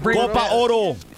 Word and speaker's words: Copa Oro: How's it pronounced? What is it? – Copa Oro.